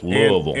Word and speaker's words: Louisville: Got to say it like you got marbles in your mouth. Louisville.